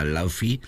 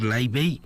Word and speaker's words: Laufey: He dicho de manera vastamente pronunciada Laufey, pero veo que se aconseja pronunciar Laufey.